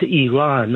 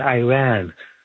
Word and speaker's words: Iran: And then that guest of yours from Idaho, it's pronounced Iran, not Iran. Like, we don't say Italy.